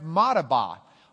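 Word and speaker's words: Madaba: It's a town that today we pronounce Madabah.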